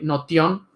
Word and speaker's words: Notion: Hay una aplicación que hay, que hay que tener un episodio donde hablemos más sobre ella, que se llama Notion, así como, como se escribe eh, Notion.